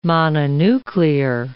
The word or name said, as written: mononuclear